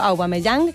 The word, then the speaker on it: Aubameyang: Esta vuelta a la Primera División que todo indica que contará con un delantero que se llama Aubameyang, perdón porque lo pronuncié mal al principio, Aubameyang,